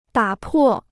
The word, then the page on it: 打破: dǎ pò